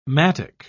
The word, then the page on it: mattock: British English